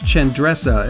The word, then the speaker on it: Qendresa: Chendressa is spelled Q-E-N-D-R-E-S-A. But it's pronounced Chendressa, and that's actually an Albanian pronunciation.